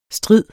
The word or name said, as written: stride